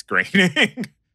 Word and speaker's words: Groening: It might have been Nintendo Power or something, but it's Groening. – Yeah. – Okay. Weird. So, yeah, Matt Groening.